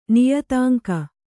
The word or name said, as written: ನಿಯತಾಂಕ